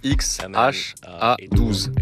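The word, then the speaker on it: X Æ A-12: I mean, it's just X, the letter X. The AE is like pronounced Ash. – Le prénom se prononce X-H-A-12. – A-12 is my contribution.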